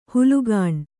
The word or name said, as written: ಹುಲುಗಾಣ್